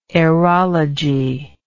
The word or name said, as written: aerologist